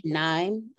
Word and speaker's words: Knime: I'm gonna say Knime. I'm going to pronounce it like it's knife because it's K-N-I-M-E.